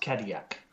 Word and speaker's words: Cadillac: But it's in French, it's pronounced Cadillac.